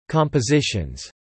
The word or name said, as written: compositions